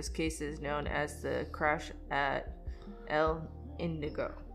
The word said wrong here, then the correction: Indio: No. Indio. Indio.